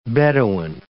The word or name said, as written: bedouin